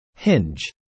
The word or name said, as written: Hinge